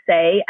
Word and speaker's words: Saie: So I don't know if you have heard of the brand Say, S-A-I-E, but pronounced Say, S-A-Y.